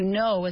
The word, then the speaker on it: know: So the K is silent. It is a silent K at the start. K-N-O-W, to know, all right?